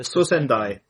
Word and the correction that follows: Cicendai: It is the Sisendai, C-I-C-E-N-D-A-I. – C-I-C-E-N-D-A-I. Ah, okay, I had it as Sosendai.